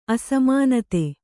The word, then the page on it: ಅಸಮಾನತೆ: asamānate